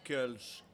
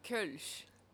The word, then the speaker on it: Kölsch: Parce que la plupart penseront à une influence belge ou européenne en général, qui vont dire Kölsch, qui, selon moi, puis mes expériences, est erronée. À vous la parole. – Euh, moi, je dirais Kölsch, personnellement. Comment on le prononce? Kölsch. – Parce que. – Kulch. Parce que le O.